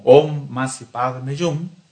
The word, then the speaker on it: Om Mani Padme Hum: Y hay una práctica que nos permite hacer esa conexión, y es el mantra Om Masi Padme Hum. Este mantra, que está escrito como Om Mani Padme Hum, pero que se pronuncia esotéricamente como Om Masi Padme Hum, nos viene a poner en contacto con esta chispa divina.